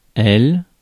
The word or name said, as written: them